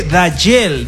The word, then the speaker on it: Dajel: Se escribe Dajel, D-A-J-E-L. ¿Por qué se lo digo? Porque al inicio del programa, la gente comenzó a decir Daniel, Jarel, Yandel, Geriel, Darkiel, Ariel, tantos nombres.